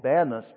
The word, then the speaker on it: Panis: In ancient history, that was called Panus, P P-A-N-I-S, Panis. Today it's called Banis because the Muslims can't pronounce the letter P. They don't have the letter P in their alphabet. So it's Banis.